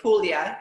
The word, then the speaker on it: Puglia: Puglia, yeah.